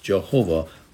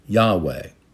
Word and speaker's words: Yahweh: In the older translations, it was pronounced Jehovah. We know more accurately, it should be pronounced Yahweh.